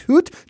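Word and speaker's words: goed: Afterward, she tweeted, "Dit team ist good," which does not mean good in Dutch, Josh. It means gold. It's pronounced hoot.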